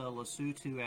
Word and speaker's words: Lesotho: So this trip was to Lesotho, Africa. It's a little nation at the bottom of the continent that is completely surrounded by South Africa. If you Google it, it's spelled Lesotho.